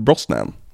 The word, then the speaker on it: Brosnahan: För det uttalas tydligen Brosnan.